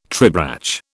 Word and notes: tribrach: British English

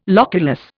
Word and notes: loculus: Indian

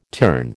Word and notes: turn: American English

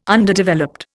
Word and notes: Underdeveloped: Indian

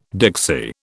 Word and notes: Dixie: British English

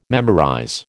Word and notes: memorize: American English

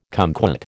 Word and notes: cumquat: American English

